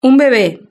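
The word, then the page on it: un bebe: Spanish (Spain)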